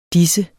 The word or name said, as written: disse